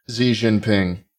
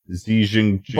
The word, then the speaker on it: Xi Jinping: Xi Jinping. – Xi Jinping.